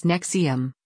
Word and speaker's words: NXIVM: The Vow is a 9-episode series looking at the NXIVM, pronounced NXIVM,